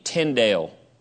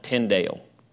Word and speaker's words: Tyndale: T-Y-N-D-A-L-E. Tyndale. Some people pronounce it Tyndale, but it's Tyndale. The Tyndale Bible. Then number five, the Geneva Bible. Geneva Bible. G-E-N-E-V-A. Geneva. G-E-N-E-V-A.